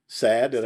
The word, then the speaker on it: Sad: Sad. I say it right? Okay. – It's like the unhappy.